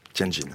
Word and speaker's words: Tianjin: Oui, Tianjin.